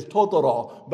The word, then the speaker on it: Totoro: The actual pronunciation is Totoro, but America, we call it My Neighbor Totoro.